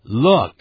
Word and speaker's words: look: 请大家看着这个单词，第四个 look， L-O-O-K， look，你看在这里这个音标呢，第一个像 L，小写的 L 一样的，要念成 l，也就是舌尖往上抵，抵到上牙齿的这个后头，